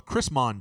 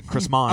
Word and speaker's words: Chrismon: There's another tradition around a tree called a Chrismon tree. – I think you said Christmas tree wrong. – No, I said Chrismon.